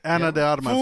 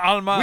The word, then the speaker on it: Ana de Armas: Ana de Armas. – You say her name right. – Alma qué? – Not Alma.